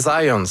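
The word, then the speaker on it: Zajonc: Non, c'est Zients. – Oui? Okay. – Ouais, j'ai-tu pas dit Zients? – Ben, j'avais dit plus Zients, mais... – J'ai prononcé un petit peu à l'anglais. J'ai prononcé mon polonais à l'anglais.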